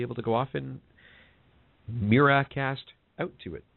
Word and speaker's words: Miracast: And it's Miracast, not Miracast. M-I-R-A-C-A-S-T.